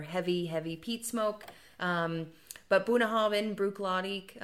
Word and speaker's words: Bunnahabhain: It can be tricky to pronounce, but again, Gaelic just kind of rushed the tricky parts. Boonahabin, you know, so that B and that H, because it's spelled B-U-N-N-A-H-A-B-H-A-I-N. Boonahabin, and you just rush it, it'll come out of your mouth perfectly, and it means foot of the river.